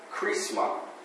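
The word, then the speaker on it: chrisma (χρῖσμα): this flowing of that through a person is a word in the Greek that sounds almost like charisma. It's pronounced chrisma.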